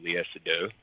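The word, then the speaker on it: Léa Seydoux: I think one of the things from Spectre that I just kind of, like, didn't buy was his relationship with, I always butcher her name, Lea Seydoux. – Yeah, there you go. – Oh, nice. Nailed it.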